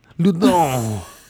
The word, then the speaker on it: Loudun: Okay, and this is in the mid 1600s in France, actually the 1630s, and so now I don't know if I'm saying it right, like Loudun, Loudun. – Okay, I like that, Loudun.